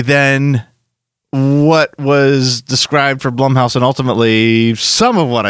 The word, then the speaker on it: Blumhouse: Rather than building an anthology house based after other movies, like Blumhouse being the, or Blumhouse, I should say. I was so conscious and to say that right, because I knew Shelby was going to get pissed and probably tweet about it if I said it wrong, and there I said it wrong.